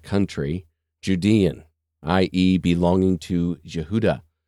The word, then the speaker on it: Yehuda: And Strong says it's derived patronymically from H3063, which word is Yehuda, which is the Hebrew pronunciation of Judah.